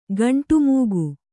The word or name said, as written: ಖಂಡಮೇಘ